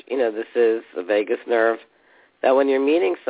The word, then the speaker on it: vagus: But my friend Grace was telling me that there's a study being done on the vagus nerve, and it's spelled V-A-G-U-S as opposed to going to Las Vegas.